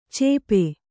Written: ಚೇಪೆ

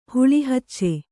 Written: ಹಲ್ಲುಹಚ್ಚು

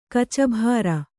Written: ಕೂಚುಭೇರಿ

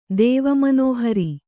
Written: ದೇವಮನೋಹರಿ

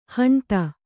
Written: ಹಣತಿ

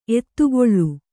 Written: ಎತ್ತುಗಲ್

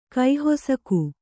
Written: ಕೈಹೊಸಕು

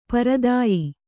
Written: ಪ್ರದೇಯ

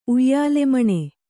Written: ಉಯ್ಯಾಲೆಮಣೆ

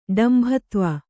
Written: ಡಂಭತ್ವ

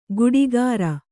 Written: ಗುಡಿಗಾರ

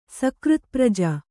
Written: ಸಕೃತ್ಪ್ರಜ

